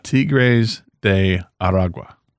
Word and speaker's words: Tigres de Aragua: Tigres de Aragua.